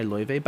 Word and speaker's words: Laufey: So this is Just Like Chet by Loive. By the way, Loive is spelled Laufey. It's Icelandic, in case you ever want to look her up.